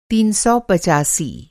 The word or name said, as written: तीन सौ पचासी